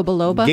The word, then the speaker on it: Biloba: Like Ginkgo Bilbo. Oh, like Ginkgo Biloba? – Ginkgo Biloba. Thank you.